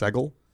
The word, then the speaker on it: Seagal: I think it's pronounced Segal. Almost positive.